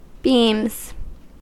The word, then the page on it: beams: American English